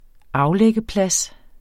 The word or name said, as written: aflæggeplads